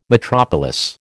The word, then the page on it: metropolis: American English